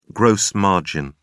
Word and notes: gross margin: British English

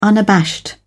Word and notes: unabashed: British English

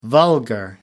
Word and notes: vulgar: American English

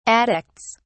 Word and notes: addicts: British English

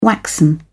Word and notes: waxen: British English

